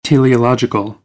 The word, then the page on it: teleological: American English; ɑː